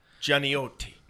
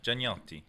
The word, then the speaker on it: Gianniotti: Gianniotti. – Yeah. – Is that right still? – Yeah, Gianniotti. – Gianniotti.